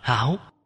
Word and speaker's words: háo: Vẽ một cái dòng chính là đọc chữ trại âm. Đọc là háo.